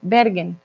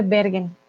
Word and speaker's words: Bergen: Ah, es en Noruega, mira, Beagen. Ay, lo estoy pronunciando alemán. Bergen. Sería Bergen. Hmm, en español. ¿Cómo se dice en español? Un momentito. Bergen. Sí, se dice Bergen.